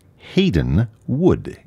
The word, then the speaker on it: Haydn Wood: Before we hear music of Joseph Haydn, we have something by an English composer who, as his first name, it has the same last name as Haydn, although it's differently pronounced because it's English. Haydn Wood is his name.